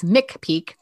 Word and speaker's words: McPeak: So like Pike's Peak, but it's Mick Peak.